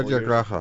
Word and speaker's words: Satyagraha: it a couple Satyagraha. – Years. – Satyagraha.